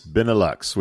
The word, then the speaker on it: Benelux: The funds will be used to develop Pera's technology, expand its footprint in the Benelux. Is that how it's pronounced? – Benelux, which is Belgium, Holland, and Luxembourg, which I didn't know.